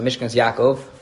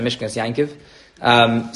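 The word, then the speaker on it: Mishkenos Yaakov: There's one opinion I believe in the Achronim of the Mishkan's Yaakov, or as I've been taught to pronounce it, the Mishkan's Yankiv.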